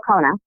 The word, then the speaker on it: Khona: Her last name is spelled K-H-O-N-A. She's also active on Twitter. What's your Twitter handle? – Rachel Kona.